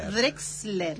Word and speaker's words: Drexler: Drexler, lo dije bien.